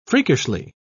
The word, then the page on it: freakishly: British English